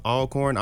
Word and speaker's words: Alcorn: It is pronounced Alcorn. I know it's not spelled that way, but we spell things differently in Mississippi.